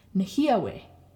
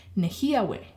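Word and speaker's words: nêhiyawê: Then if you want to say speak Cree to someone, nehyawe. because that's four syllables, okay? And the third last syllable is the he. Nehiawe. So speak Cree.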